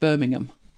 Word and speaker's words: Birmingham: It's like Birmingham and Birmingham. With us, it's a silent H, Birmingham.